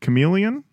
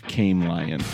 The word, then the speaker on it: chameleon: Is it Chameleon? – Yeah. – There we go. – That's better than your first guess of Came Lion.